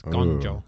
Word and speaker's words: Guangzhou: Dueling Intamin coasters are being built in Wanda Guangzhou, China, and I actually looked up how to pronounce that. It's Guangzhou.